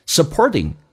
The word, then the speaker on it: supporting: Supporting 这个单词，美式发音那个 t 要浊化，两个元音中间所加的辅音要被浊化。